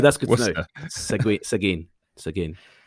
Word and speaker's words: Seguin: So we had a tweet as well from someone who picked up on our last episode when we talked about Seguin and the pecan, and apparently we got that all wrong. So this guy Eric Burkholder tweeted us saying, boys, native Texan here, it's Seguin.